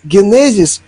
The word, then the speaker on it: генезис: если парашют через «у» будет и генезис, ударение, генезис, ударение на втором слоге.